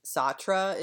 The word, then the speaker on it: Sartre: It's the, well, I guess it's Sartre is how you say his name,